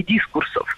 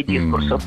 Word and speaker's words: дискурс: Ну вот в академическом поле это бы назвали изменение дискурсов или различие дискурсов. – Не дискурс, а дискурс. Ударение в этом слове всегда ставим на второй слог. И при склонении ударение не меняем. Дискурс, дискурса, дискурсов.